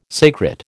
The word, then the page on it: sacred: American English